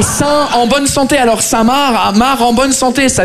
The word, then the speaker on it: Saint-Marc: On est d'accord, les Français, que Saint-Marc, ça s'écrit M-A-R-C, comme le prénom Marc. Mais vous, vous mettez un saint devant le prénom Marc, le C, il est parti en grève.